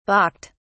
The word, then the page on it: balked: British English